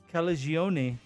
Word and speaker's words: Calagione: Oh yeah, one of those uh, buzz, one of the buzz games uh, featuring the brewery's founder and Sam Brewer, Sam Calagione. Hey, I actually I think I pronounced that correctly this time.